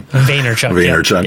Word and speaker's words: Vaynerchuk: Gary Vaynerchuk, I think is how you say his last name. – Vaynerchuk. Yeah.